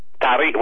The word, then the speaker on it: Tarija: And am I pronouncing it right? Tarija? – Tarija.